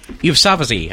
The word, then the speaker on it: Yousafzai: And before we get into some more fun news, we do want to update you on Malala Yousafzai. How do you pronounce it? – Yousafzai. – Yousafzai. Thank you.